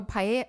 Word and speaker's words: Paia: I don't know if I'm saying it right, but Paia. – Oh, Paia Town in Maui? – Yeah.